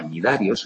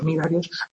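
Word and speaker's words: Cnidarios: Muy bien, pues fijaos, hasta hace muy poquito, este de tenóforos, que se estaba junto a este otro que también tiene una C delante, que se llama Nidarios, o sea, tanto los dos que tienen C que no se pronuncian, o sea, los Ctenóforos y los Nidarios, hasta hace muy poquito, porque esta es una la ciencia, la taxonomía, es una ciencia que está en continua evolución.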